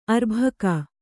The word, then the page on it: ಅರ್ಭಿಕ್ಕು: arbhikku